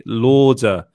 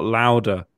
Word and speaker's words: Lauder: and at EJ Lauder, L-A-U-D-E-R, not Louder, it's Lauder.